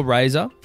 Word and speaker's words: Razr: The Motorola Razr, styled R-A-Z-R, pronounced Razr,